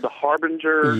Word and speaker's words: The Harbinger: Is it called The Harbinger? – Yeah, very well pronounced.